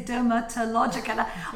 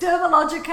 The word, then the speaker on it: Dermalogica: They say Dermatologica. I started saying it's Dermalogica and the T is silent, you know, but that's okay.